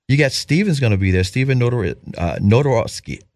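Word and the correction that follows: Nedoroscik: I cannot pronounce his name properly. – Nedorosnik. Yeah, he's got a hard one. Yeah, Nedorosnik.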